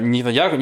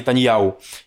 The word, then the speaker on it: Нетаньяху: Не «цахал», а «цааль», не «шабак», а «шабак» э, Нетаньяху, не Нетаньяху, а не Нетаньяу и так далее.